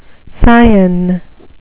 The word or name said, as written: scion